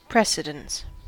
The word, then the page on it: precedence: American English